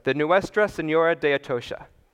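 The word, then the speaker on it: Nuestra Señora de Atocha: And pretty much all this was being put onto the ship, the Nuestra, or the Senora, hang on, I'm gonna get this right, the Nuestra Senora de Atocha. I'm looking at my, okay, I said that right.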